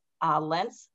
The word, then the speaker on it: Lentz: So that's K-R-I-S-T-I-N-A. And I have two last names, Lentz Capano, L-E-N-T as in Tom, Z as in Zebra, Capano, C-A-P-A-N-O.